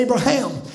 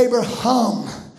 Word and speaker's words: Abraham: He said, Do you see in the Hebrew it's it's pronounced different than the English? He said, You say Abraham. He said, We say Abraham. He said the Hebrew H cannot be pronounced without breathing across it.